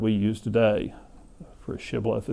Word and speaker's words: Shibboleth: They say, "Oh, you're not an Ephraimite, then say Shibboleth." And if you're an Ephraimite, apparently you've had trouble pronouncing that sound, and they would say Sibboleth.